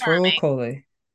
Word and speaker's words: Rahul Kohli: who is played by Rahul Kohli, and I hope I'm saying the name right. – Yes, Rahul Kohli. He is so charming and handsome and funny and amazing.